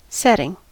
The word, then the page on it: setting: American English